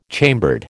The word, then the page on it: chambered: American English